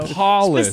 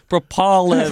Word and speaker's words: propolis: Propolis. – Propolis. – That's where the pollen comes from. – You were pronouncing it wrong, and propolis. – Me and Ethan didn't understand. – She sent it phonetically.